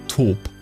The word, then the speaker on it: Taupe: Du dachtest, es heißt Taupe, aber es heißt Taupe.